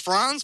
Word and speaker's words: Franz: Hi, it's Franz, but close enough. – Franz Maruna. Okay, good. It's more of a German pronunciation then.